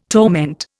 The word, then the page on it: torment: Indian